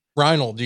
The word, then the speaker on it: Reinold: Reinold. I'm good. – How do I say that? – Reinold. You got it right the second Reinold? Time. I'm Reinold.